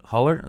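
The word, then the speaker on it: Hüller: Huller.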